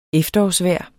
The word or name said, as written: efterårsvejr